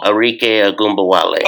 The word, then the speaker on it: Arike Ogunbowale: Like, Arike, or what's that, how you say her name? Erica, whatever. – Arike Ogunbowale.